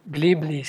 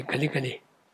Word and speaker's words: glibly: Some people may glibly say, did I say it right? Glibly. I didn't say gully.